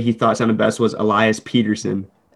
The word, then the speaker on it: Elias: Because I'm seeing more people just pronounce it Elias. – I think the Swedish, he had that one interview in his rookie season. He said the Swedish way was Elias Pettersson.